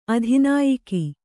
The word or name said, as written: ಅಧಿನಾಯಕ